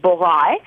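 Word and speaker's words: Bourail: So about 2 hours north of Noumea is in a beachside town called Borai, which is spelled Borail, is a New Zealand war cemetery.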